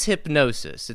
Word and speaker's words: Hypgnosis: The name is Hypnosis. It's spelled weird. Hypgnosis. Like Gnosis, like Gnostic almost, you know?